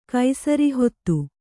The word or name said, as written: ಕೈಸರಿಹೊತ್ತು